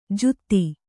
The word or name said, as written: ಜೂತಿ